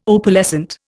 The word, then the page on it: Opalescent: Indian